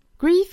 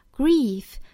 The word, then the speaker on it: grief: Grief can drive people to extraordinary lengths. 首先我们来看语音方面 Grief 这个单词里面含有一个 e，但是呢我们要注意它的发音是长元音 e 的发音 e， grief， grief， grief can drive，